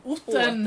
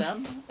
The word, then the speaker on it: Alvesta: Men med uttalet Otten. Åten. Otten ja.